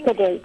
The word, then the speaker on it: pide: Upper Waseela, we need to take a quick ad break, and then when we come back, hopefully you're going to share the Turkish pie dish with us. – Turkish pide. – Is it pide? Okay.